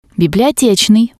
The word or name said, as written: bibliotetsnii